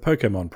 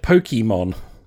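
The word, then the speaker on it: Pokémon: One of the many, many things I didn't like about this film is the fact that no one could pronounce the word Pokemon properly. They all said Pokemon.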